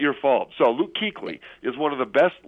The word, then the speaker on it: Kuechly: Now, what they did do, though, was they spell his name in a way to confuse basketball fans, because it is pronounced Kuechly, but that's not your fault.